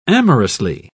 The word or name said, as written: amorously